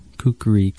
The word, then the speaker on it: Kukri: Um, I did not know that there were three syllables to it. Yeah. Kukri.